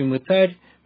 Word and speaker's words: мытарь: Возможно, вы, уважаемые радиослушатели, привычны к иному ударению в этом слове. Что делают мытари? Так говорит большинство. Однако, если мы посмотрим на то, как сама Библия ставит ударение в этом слове, мы обнаружим следующее. Книга Евангелия от Матфея, 18 глава, 17 стих. Матфея, 18 глава, стих 17. «Если же не послушает их, скажи церкви, а если и церкви не послушает, то да будет он тебе, как язычник, и мытарь». В слове «мытарь» прямо-таки стоит ударение на последнем слоге.